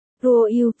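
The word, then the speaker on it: Ruoyu: Ruo yêu, phiên âm tiếng Hán là Ruo yêu, Nhược Ngữ, Ruo yêu, phiên âm là Ruo yêu, cách đọc của hai từ này giống nhau nên Phượng Hiên hiểu lầm, biểu ca có món gì ngon.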